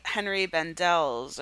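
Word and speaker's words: Henri Bendel: Because he worked at, like, Henry Bendel's, or is that how you say it? – Yeah.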